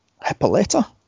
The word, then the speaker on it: Hippolyta: So she's caught by her mother, Queen Hippolyta.